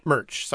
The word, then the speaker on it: Murch: So, directed by Walter Merck. It was the only thing he ever did. – Merck or Murch? – Murch, sorry. – Spell it. – M-U-R-C-H. – Oh, Okay, like Lurch, but with an M.